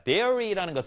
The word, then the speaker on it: dairy: D-I-A-R-Y고요. 지금 여기 적혀 있는 것은 D-A-I-R-Y입니다. dairy라고 있습니다. dairy라는 것은 유제품의, 이 우유와 관련된 낙농의라는 의미고요. 그래서 여기에 가장 흔한 dairy cow라고 읽어야 됩니다. diary cow 아닙니다.